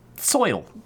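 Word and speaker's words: Zeuhl: Zoil. Or soil. Soil. Zoil. I don't know. It's Z-E-U-H-L, but it's pronounced... Soil, like T-S-O-I-L. Maybe just say soil and leave it at that. – Yeah, S-O-I-L.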